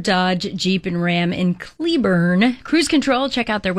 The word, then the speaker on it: Cleburne: He's the GM of Southwest Chrysler, Dodge, Jeep, and Ram in Cleburne. Wow, that was hard to say. Let me go again. – Cleburne. No, they say it that way. That's how they say it. Cleburne.